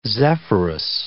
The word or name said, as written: Zephyrus